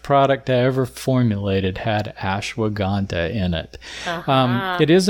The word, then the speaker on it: Ashwagandha: And what is the one that I'm not going to be able to pronounce? Ashwagandha? – Ashwagandha. – Oh, well, I was closer than I thought.